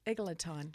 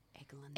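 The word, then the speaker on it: Eglantyne: Anyway, back to Eglantine. – Eglantine. – Eglantine. – Can you spell it? – E-G. – Yep. – Just one G. – Yep. – L-A-N. – L-A-N. Eglan. – T-Y-N-E. – Eglantine.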